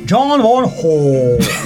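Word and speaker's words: Bong Joon-ho: Mi dici John Boone? – John Won Ho. – Benissimo. – Coreano, accento coreano.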